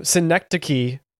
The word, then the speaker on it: Synecdoche: Synecdoche, New York.